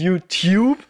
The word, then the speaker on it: YouTube: Das YouTube mit der Betonung auf der Tube, ja.